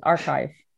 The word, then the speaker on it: arXiv: Arvix, yeah. – Archive, BioArchive, okay. MetArchive. Yeah, you say right. That's how you pronounce it. Archive.